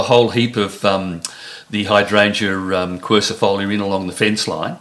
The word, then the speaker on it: quercifolia: Now they're, of course, closely followed on my list by the quercifolia.